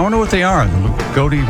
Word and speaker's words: Loogootee: L-O-O-G-O-O-T-E-E. – Lagodi? – Lagodi, Indiana.